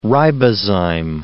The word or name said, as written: ribozyme